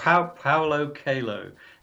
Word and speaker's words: Paulo Coelho: It's Paulo Coelho. It's Portuguese. It's a Portuguese name.